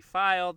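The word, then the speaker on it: Feil: I am on Twitter at Chris V File. That's F-E-I-L.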